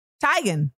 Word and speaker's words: Teigen: I didn't even know this, but in 2018, apparently Chrissy Teigen revealed that her name is actually pronounced Teigen. I did a whole show with the lady. I did not know Chrissy's name was pronounced Chrissy Teigen.